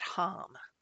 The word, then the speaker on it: ham: Its tone is hum. It's spelled ham, but you say it hum.